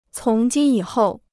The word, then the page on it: 从今以后: cóng jīn yǐ hòu